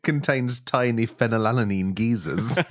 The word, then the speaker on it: phenylalanine: Caffeine, phosphoric acid, petrol, citric acid, phenylalanine. – Ooh, say that again. – Phenylalanine. – I'll say it right. – Phenylalanine. – That's better.